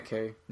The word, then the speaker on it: Nikkei: Neki, Nikai, Nikki. It's called a Nikkei. Nikkei. Okay.